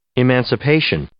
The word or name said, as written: emancipation